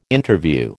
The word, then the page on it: Interview: American English